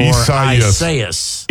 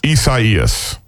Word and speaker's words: Isaias: And and the big question, how do you pronounce it? Esaias or Isaias? – Esaias. – Esaias is definitely the Weather Channel's the Spanish pronunciation.